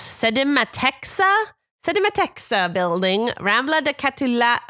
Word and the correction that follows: Catalunya: oh my God, Rambla de Catalunya, 34.